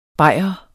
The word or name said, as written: BAYRER